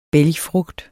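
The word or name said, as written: BÆLGFRUGT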